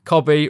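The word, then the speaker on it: Kobbie: All he needs to do is remember Ian's, you know, saying, Cobby rhymes with Robbie, like Robbie Williams, who was a member of your favourite band.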